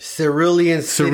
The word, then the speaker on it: Cerulean City: I'm pretty sure I'm going to pronounce this wrong, but I'm guessing it's Cruellian City. – Oh my God. – Is it? Or how do you pronounce it? – Cerulean City. – Cerulean City. Oh, gee, sorry.